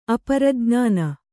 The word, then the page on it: ಅಪರಜ್ಞಾನ: kannada